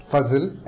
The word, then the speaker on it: Fazal: I am Fazil Ahmad Shah. Fazil, F-A-Z-A-L, that's how it's pronounced.